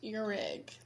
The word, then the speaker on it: Ihrig: It's pronounced Irhig, like you have an Eric. – ear and then a rig.